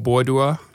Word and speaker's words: Boadua: And I was born in a small village called Bodua, and it's spelled B-O-A-D-U-A.